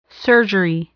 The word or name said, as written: surgery